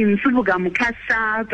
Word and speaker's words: Nsubuga-Mukasa: I'm going to put an accent on. It's actually NSUBUGA-MUKASA, but I'll take anything you can give me.